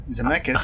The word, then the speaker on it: Zemeckis: Written and directed by Lee Zemeck. Zemeckis, sorry.